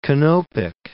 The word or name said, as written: Canopic